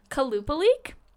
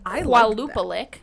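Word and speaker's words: Qalupalik: But it's spelt like with a Q. So it's like Kalupalik. It looks like it's supposed to be like Kualupalik. – Like but it's actually Kalupalik.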